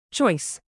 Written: choice

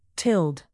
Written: tilled

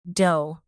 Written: dough

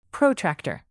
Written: protractor

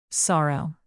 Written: sorrow

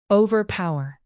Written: overpower

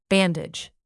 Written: bandage

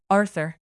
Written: arthur

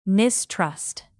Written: mistrust

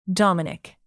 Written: dominic

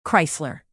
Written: chrysler

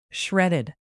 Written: shredded

